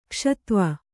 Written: ಕ್ಷತ್ವ

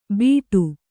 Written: ಬಿಡು

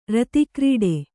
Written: ರತಕ್ರೀಡೆ

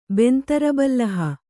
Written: ಬೆಂತರಬಲ್ಲಹ

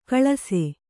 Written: ಕಲಿಸು

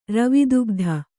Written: ರವಿದುಗ್ಧ

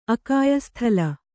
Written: ಅಕಾಯಸ್ಥಲ

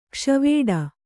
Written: ಗಾಸಿವಡೆ

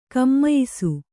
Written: ಘಮಾಯಿಸು

